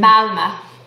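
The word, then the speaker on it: Malmö: place called Malmo. – Malmo, is that the correct pronunciation? – Malma, yeah. – Ma, Malma uh,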